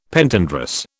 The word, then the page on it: Pentandrous: British English